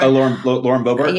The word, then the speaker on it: Boebert: And like we saw, you know, prior to this, like the likes of, I don't even know how to say her name, Bobart. Is that it? Lauren Boebert? – Lauren Boebert.